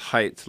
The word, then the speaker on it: Haidt: It's pronounced Haidt, like height, but it's H-A-I-D-T.